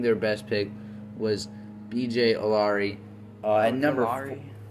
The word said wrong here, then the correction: Ojulari: Did I pronounce it wrong? – Ojulari. – I'm sorry. B.J. Ojulari at number 41, just because it looked like he could be in the first round.